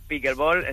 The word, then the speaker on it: pickleball: ¿Lo he dicho bien? ¿Cómo se dice? ¿Pickleball, pickleball? – Pickleball, está todo perfecto. Sean nombres así un poco anglosajones, estamos en España, así que pickleball, se lo puede decir.